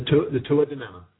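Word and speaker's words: Tuatha Dé Danann: I do know a little bit about Irish folklore, very little, but I do know about please correct me if I'm saying this wrong. The Tuatha De Danann? – The Tuatha De Danann. – Tuatha De Danann? Is that how you say it? – Tuatha De Danann. Yeah, Tuatha De Danann.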